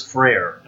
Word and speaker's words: Frayer: Well, my last name is Frayer, and it's spelled just like prayer, but with an F.